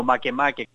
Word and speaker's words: Makemake: Es como Makemake, el planeta enano Makemake, que se escribe, para ellos se escribe Make Make, pero se dice Makemake, y a ellos se quedan muy confundidos con eso.